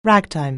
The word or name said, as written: ragtime